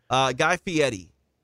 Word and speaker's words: Guy Fieri: Guy Fieri. – Fieri. F-I-E-R. – Correct. Oh, yeah. It doesn't look like it, but yeah, Guy Fieri.